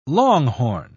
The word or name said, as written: longhorn